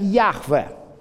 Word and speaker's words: Яхве: С точки зрения грамматики, это имя произносится как «Яхве».